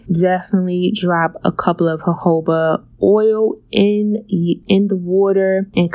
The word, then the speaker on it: jojoba: The next option for essential oil that I like to use is jojoba. It's spelled J-O-J-O-B-A. It's Spanish, so that is how it's actually pronounced.